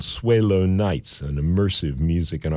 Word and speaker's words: Swaylo: His name is spelled S-W-A-Y-L-O, Swelo.